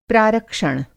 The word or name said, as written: प्रारक्षण